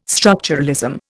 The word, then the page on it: structuralism: Indian